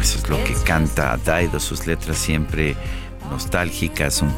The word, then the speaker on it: Dido: Se escribe Dido, pero la pronunciación en inglés es Daido.